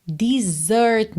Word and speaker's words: desert: Desert me, atenção à pronúncia dessa palavra, tá? Porque essa palavra se escreve igual à palavra desert, que é o deserto, tá? Mas aqui é o verbo desertar, abandonar, tá bem? Então, a pronúncia muda e fica desert.